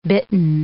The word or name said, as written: bitten